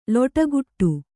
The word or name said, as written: ಲಟಕಟ